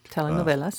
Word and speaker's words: telenovelas: I got to say that right. – Yep, telenovelas.